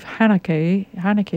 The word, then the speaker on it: Haneke: If you haven't seen it, it's by the provocative writer-director Michael, I believe, Haneke is how he pronounces his name.